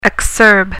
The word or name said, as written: exurb